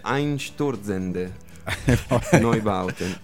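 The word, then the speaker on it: Einstürzende Neubauten: No, non è corretta. La pronuncia corretta sarebbe Einsturzende Neubauten.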